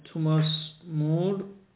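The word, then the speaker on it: Thomas More: این توماس مور، تامس مور، البته به آلمانی تلفظش توماس هم میشه،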